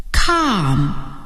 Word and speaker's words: calm: Calm. Keep calm. 今天时间很短，不过我们学了字母组合 AL 的发音，它们可以发 O 和 A。